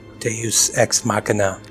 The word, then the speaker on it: Deus Ex Machina: Deus Ex Machina.